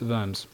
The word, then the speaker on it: Worms: Germans don't pronounce the W, so it's Worms.